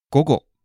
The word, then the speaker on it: 午後: 午後午後 Great.